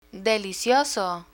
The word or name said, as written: delicioso